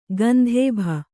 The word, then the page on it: ಗಂಧೇಭ: Kannada